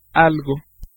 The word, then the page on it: anything: /ˈɛ.ni.θɪŋ/